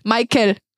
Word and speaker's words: Meikel: M-E-I-K-E-L, Michael. Weil wir dann noch so, okay, klingt wie Michael, so.